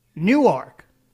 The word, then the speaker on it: Newark: the town in Delaware is spelled just like Newark, New Jersey, but it is pronounced Newark, even though it's spelled the same as Newark.